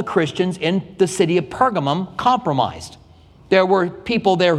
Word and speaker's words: Pergamum: And I think to some degree, we will find ourselves in Pergamum. Pergamum or Pergamos, depending on what translation you're using or who you're listening to, both of them are ways that the city is pronounced, is the compromising church.